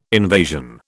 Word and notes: invasion: British English

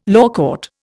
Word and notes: lawcourt: Indian

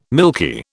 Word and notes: milky: British English

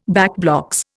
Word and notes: backblocks: Indian

